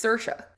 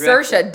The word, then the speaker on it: Saoirse: But you're not even, it's Saoirse. It's not Cersei. – Right, exactly. – It's Saoirse, dumbass.